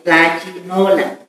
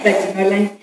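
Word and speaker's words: Tlachinollan: También quiero manifestar el agradecimiento al equipo de Tlachinoyan y a la, nunca lo puedo pronunciar bien, Tlachinolan, ¿verdad? Así es, Tlachinolan, y a toda la organización por su lucha constante y el apoyo que le han dado a Valentina y a otras.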